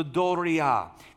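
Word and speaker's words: Doria (δωρεά): Doria. It's spelled D-O-R-E-A. In Texas, we can say it, the doria. But it's sounded out, Doria.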